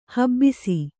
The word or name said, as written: ಹಬ್ಬುಸೆ